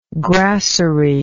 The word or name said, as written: grasserie